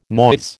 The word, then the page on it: noise: American English